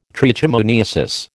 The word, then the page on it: trichomoniasis: American English